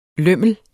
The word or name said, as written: lømmel